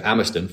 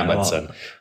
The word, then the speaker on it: Amundsen: So Amundsen from Norway. – Amundsen. – Yeah. Am I pronouncing it wrong? – Yeah, Amundsen.